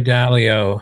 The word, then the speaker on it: Dalio: I mean, again, going back to Ray Dalio, if I'm pronouncing that right.